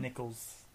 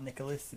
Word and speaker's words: Nichols: Nichols. It's not Nicholas, it's Nichols.